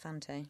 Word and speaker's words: Fante: I would say you pronounce that Fante. – Fante. – One would think.